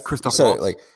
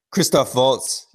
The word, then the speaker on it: Christoph Waltz: from like the quotes Christoph – how did you say it? – Christoph Waltz. – Christoph Waltz, yeah, from the quotes that he – the quotes that he gave, it sounded like it was a studio's decision.